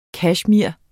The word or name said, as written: kashmir